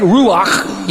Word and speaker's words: ruach: It's the word ruach. Now, in order to say it right, you have to spit at the end.